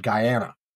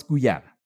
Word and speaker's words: Guyana: I, apparently, I don't even know the pronunciation because I would have said Guyana. – Yeah, uh, well, it's Guyana.